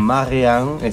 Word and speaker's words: Marianne: Marianne, exactamente. Marianne Kroll. – Mm. – Francesa. a ver, sí, la pronunciación es francesa.